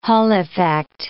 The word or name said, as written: Hall effect